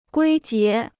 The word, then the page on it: 归结: guī jié